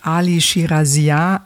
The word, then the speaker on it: Ali Shirazinia: So, und einer von den beiden Dubfire, eigentlich Ali Shirazinia, so spricht man ihn aus, genau.